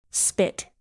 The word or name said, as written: spit